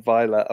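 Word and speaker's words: Vejle: Secondly, I've had some very helpful people on Twitter tell me how to pronounce these. So Sunayuska and Vela are both looking like, at the moment, relegation is going to happen, unfortunately.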